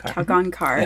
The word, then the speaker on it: Kalgonkar: Kalgonkar. – Yeah. Okay, it's phonetic like mine, Talkowski.